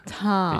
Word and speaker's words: Tom: Tom. Fechar a boca com M. – Tom. Só tem um errinho de pronúncia, que é fechar a boca no final das palavras com um M.